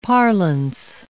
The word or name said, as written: parlance